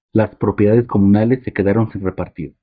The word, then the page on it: repartir: spanisch